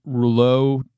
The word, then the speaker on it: Reuleaux: And if I remember right, I don't know how to pronounce this word. It's R-E-U-L. It's French. R-E-U-L-E-A-U-X triangle. That's the actual word. Mm-hmm. Rouleau triangle. – Sure. – That sounds like that might be correct.